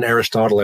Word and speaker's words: Aristotle: The first time I mention Aristotle, I have a footnote that says rhymes with Chipotle.